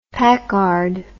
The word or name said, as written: packard